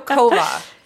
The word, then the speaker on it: Coava: So Coava. This is the pronunciation. – Coava. Yeah. Even though it's spelled C-O-A-V-A. – Yes. And Coava. – But I could see that like coat, like C-O-A, Coava. – Yeah. It's a Spanish word, and it means green coffee.